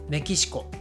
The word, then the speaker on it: メキシコ: ね、正式にはメキシコ合衆国と言いますが、これもね、アメリカ合衆国と同じでほとんどの日本人はメキシコはいメキシコと言っていますですから 1 つ目のねメキシコ